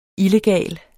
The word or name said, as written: illegal